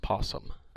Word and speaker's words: opossum: I believe it's pronounced possum. – So why would you put in... This is what I'm saying. Why would you put an O at the beginning of a word? Like... – It's silent.